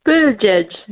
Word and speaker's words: Buttigieg: Buttigieg. It's like saying edge twice.